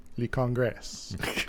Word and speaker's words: Le Congrès: Yes, it is, French for the Congress, but it is Le Congrès.